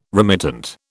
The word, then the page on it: remittent: British English